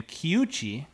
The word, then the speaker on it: Kiuchi: So I've never heard of this because I don't live in Japan, but Japanese brewery, hopefully I'm not butchering the name, Kiuchi, K-I-U-C-H-I, Kiuchi Brewery. And what we're about to try is the Hitachino, H-I-T-A, Chino, Hitachino Nest Espresso Stout.